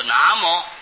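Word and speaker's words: 南無: 从前那个南字啊，都是念作那，无啊就是摩，啊、就是本来这是客家话，有摩，有无就是有摩。那么本来这个无啊就是客家话的摩，啊、所以要念作南摩，应当是以客家话来念最正确了。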